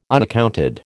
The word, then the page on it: unaccounted: American English